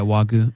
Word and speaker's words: Wagyu: It was like Wagyu. Is that how you say it? – Yeah, Wagyu.